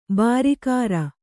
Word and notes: ಭರಿಕರ: bhari kara

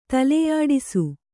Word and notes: ತಲೆಯಾಡಿಸು: taleyāḍisu

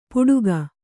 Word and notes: ಪುಡುಗ: Kannada

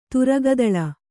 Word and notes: ತುರಗದಳ: Kannada

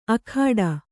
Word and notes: ಅಖಾಡ: akhāḍa

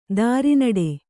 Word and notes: ದುರ್ನಡೆ: kannada

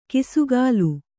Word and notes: ಕಿಸುಗಾಲು: kisugālu

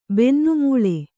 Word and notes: ಬೆನ್ನುಮೂಳೆ: bennu mūḷe

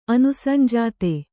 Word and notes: ಅನುಸಂಜಾತೆ: Kannada

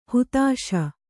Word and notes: ಹತ್ತಿಸು: hattisu

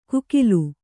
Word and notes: ಕೂಕಿಲು: kūkilu

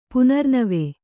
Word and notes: ಪುನರ್ನವಿ: punarnavi

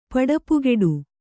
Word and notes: ಪಡಪುಗೆಡು: paḍapugeḍu

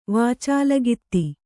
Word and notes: ವಾಚಾಲಗಿತ್ತಿ: kannada

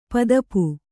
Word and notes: ಪದುಪು: padupu

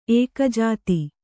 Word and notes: ಏಕಜಾತಿ: ēkajāti

